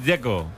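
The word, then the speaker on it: Džeko: Porque DZ, ¿cómo se pronuncia? – Dzeko. Bruno dice que es Dzeko. – Como tirando a J, Dzeko o algo así.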